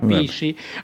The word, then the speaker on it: PSSI: Io ho fondato il mio partito, Partito Super Sovranista Italiano, PSSI, lo pronunciamo Pissi.